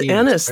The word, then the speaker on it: Ennis: But when I say that, I'm only talking about like shared thoughts from, I think, how do you pronounce it? Enis or Ennis? – I think it's Ennis. Right? That's how they said it in the movie.